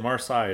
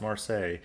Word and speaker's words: Marseille: So this, anytime you hear, it's like last week I said Marseille, it's Marseille.